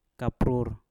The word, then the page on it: kaprur: Mpur